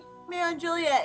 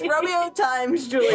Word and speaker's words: Romeo x Juliet: Romeo and Juliet. It's Romeo x Juliet. Say it right. – Okay, it's Romeo x Juliet, but when you watch... – Romeo times Juliet. – Yes, it's Romeo times Juliet.